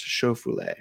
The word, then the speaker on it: Schauffele: Schofoulet I don't know if I'm saying that Schauffele. Sh- uh, Schaefer, isn't it? Or is it? – I believe it's pronounced Schauffele.